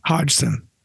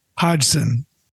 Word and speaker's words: Hodgson: Hodgson. – Hodgson? – Hodgson.